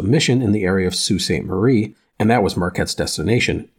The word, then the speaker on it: Sault Ste. Marie: Marie, S-A-U-L-T, but it is pronounced Sault Ste. Marie. Just wanted to let you know that.